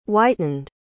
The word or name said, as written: whitened